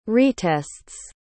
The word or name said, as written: retests